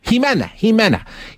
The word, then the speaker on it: Ximena: Jimena.